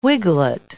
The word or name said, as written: wiglet